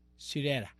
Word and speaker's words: Syreeta: ¿Cómo se pronuncia esto? Syreeta Wright. ¿Syreeta? Esa. Syreeta.